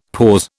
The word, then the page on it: pause: British English